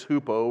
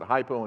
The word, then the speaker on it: hupo: And so that's the same prefix, hupo, or we pronounce it hypo in English.